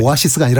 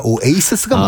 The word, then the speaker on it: Oasis: 오아시스가 아니라 오에이시스가 맞습니다.